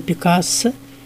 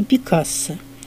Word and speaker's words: Пикассо: И напоминаем, что у нас есть два варианта его произношения. По-французски «Пикассо» и по-испански «Пикассо». И последние советы орфоэпистов лучше уж говорить «Пикассо», как мы привыкли считать это нашей традицией.